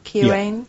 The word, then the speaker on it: Keohane: Do I say that right? K-E-O-H-A-N-E? Keohane? Yeah. Keohane?